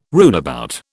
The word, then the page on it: runabout: British English